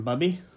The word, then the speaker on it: Bubby: And then I don't want to say it wrong. Bubby? – That's right.